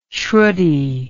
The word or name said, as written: sruti